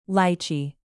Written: lychee

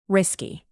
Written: risky

